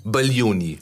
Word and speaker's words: Baglioni: Baglioni. Claudio Baglioni. Baglioni. – Siehst Baglioni. du? G spricht man nicht und dieser Claudio hat offenbar was damit zu tun.